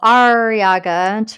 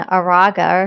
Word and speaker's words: Arreaga: Well, I don't remember, but I had to go Arreaga to remember how to. Yeah, I, it, I, said right? like a pirate, Sheena Arreaga, and, that helped her keep it straight because it.